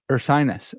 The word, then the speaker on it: Ursinus: He's a professor of philosophy at I, he just told me how to say it, but it's Ursinus, Ursinus. I believe it's Ursinus.